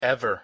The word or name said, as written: ever